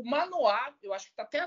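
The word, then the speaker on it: Manoah: É Manoá, acho que eu até pronunciei errado. – Manoá.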